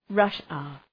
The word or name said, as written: rush hour